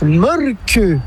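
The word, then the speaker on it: Mölkky: Inventé en Finlande en 1996, ce jeu de quilles s'écrit M-O-T-R-E-M-A-L-K-K-Y. – Ça s'écrit Molki, mais ça se prononce Molke. – Le Molki. – Plutôt un E qu'un E, mais pas un U. Comme ça fait Molke.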